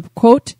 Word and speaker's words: Pequot: Preston and Norwich were originally the territory of the Pequot tribe and I'm hoping I'm saying that right. It's the P E Q U O T tribe.